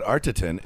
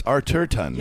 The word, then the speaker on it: Arterton: It's not Artitin. It's Artertun.